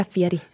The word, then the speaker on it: Fieri: She was Southern like the guy Fieri. Is that how you pronounce it? – Yeah, Fieri.